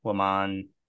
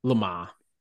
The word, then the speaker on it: Le Mans: The Indy 500, Le Mans. I'm saying that wrong, aren't Le Mans.